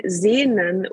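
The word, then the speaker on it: Sehnsucht: So you spell it S-E-H-N, Sehn, and then S-U-C-H-T. And see? Now the interesting thing is that the Sehn, the first part, the S-E-H-N, comes one from seeing.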